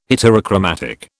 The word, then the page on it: heterochromatic: British English